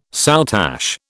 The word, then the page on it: soutache: British English